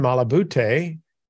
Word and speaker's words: Malabute: and his name is Jason Malabute.